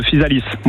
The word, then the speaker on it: Physalis: Vous dites Physalis ou Physalis? Vous prononcez le S ou pas? – Je Physalis, moi je prononce le S.